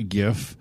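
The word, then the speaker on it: Giff: And it is with a hard G, a GIF, who is the space hippo race from the Spelljammer setting of D&D.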